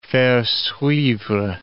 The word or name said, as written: faire suivre